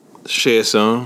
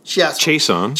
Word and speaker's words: Chiasson: Chiasson. – Of course, two points. – Sorry, I said that wrong. – Chiasson, 2 points.